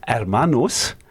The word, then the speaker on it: hermanos: I pronounced the H then, and I shouldn't have done. So it's hermanos, brothers, and hermanas, sisters.